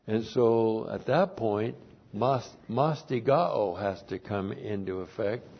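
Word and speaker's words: Mastigao (μαστιγόω): Mastigao, M-A-S-T-I-G-O, long O.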